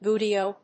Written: buteo

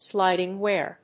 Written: sliding wear

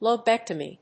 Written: lobectomy

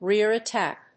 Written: rear attack

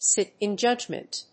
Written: sit in judgment

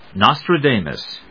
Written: nostradamus

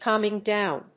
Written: calming down

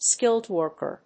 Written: skilled worker

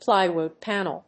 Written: plywood panel

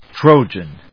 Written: Trojan

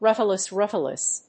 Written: rutilus rutilus

